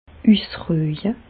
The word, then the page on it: üsrüje: Bas-Rhin; Reichshoffen